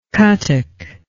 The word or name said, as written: cuttack